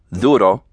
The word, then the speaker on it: duro: First of all, the Spanish D is usually pronounced like the TH of they in English. Therefore, the D of duro sounds like the TH of they. – Duro.